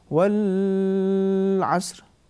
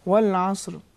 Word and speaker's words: والعصر: مثال کے طور پر پہلی آیت جو آپ نے شروع کیا والعصر, اگر ہم اس طریقے سے پڑھیں والعصر صحیح ہے یا نہیں؟ نہیں, لام کی آواز کو ہم نے کھینچا, یہ کھینچنا صحیح نہیں ہے.